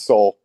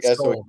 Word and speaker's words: Sohl: It's Soul. It's like Soul.